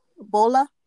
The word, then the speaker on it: Bola: What is it? – Bola. It's B-O-L-A. Her last name is spelled S-O-K-U-N-B-I.